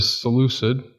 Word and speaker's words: Seleucid: the Seleucid.